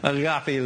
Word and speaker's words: agape: We say agape, but it isn't how you say it. – Agape. – Yeah, okay. Agape.